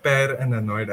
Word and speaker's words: Per Annoyed: Yeah, it's just Per and Annoyed. It's a Per. Little bit of a pun. Yeah. So okay. Paranoid. Yeah.